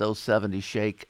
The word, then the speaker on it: 070 Shake: Yeah, it's 070, but I think it's pronounced 070 Shake, who also has another new track that just came out a couple days ago.